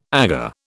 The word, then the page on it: Aga: British English